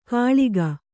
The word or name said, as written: ಕಾಳಿಕ